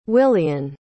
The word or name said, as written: willian